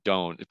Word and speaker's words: Don: So don't, the letter for the Enochian letter R is don't, pronounced don't. So if I see the Enochian letters D-O-N or the equivalent of those,